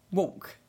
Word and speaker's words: walk: Walk. – Walk. – What's wrong? Walk. I talk. I walk. – Are you pronouncing the L?